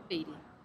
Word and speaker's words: Beatty: Beatty. – Beatty? – Yes. – Because it's spelled B-E-A-T-T-Y, right? – Correct.